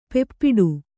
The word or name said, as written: ಪಾಪಟಿ